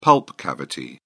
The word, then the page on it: pulp cavity: British English